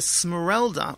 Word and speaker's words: Costa Smeralda: The Smeralda, which we're doing three times this summer, and you did pronounce it correctly.